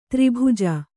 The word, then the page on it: ತ್ರಿಭುಜ: tri bhuja